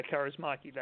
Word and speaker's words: Kaurismäki: Yeah, Kaurismäki, that's right.